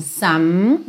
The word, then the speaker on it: some: Então, some, sem pronunciar a letra E.